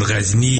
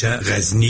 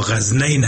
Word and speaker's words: غزنی: بعضی ها غزنین هم تلفظ می کرده، مایی درست نیست.